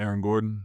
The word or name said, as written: Aaron Gordon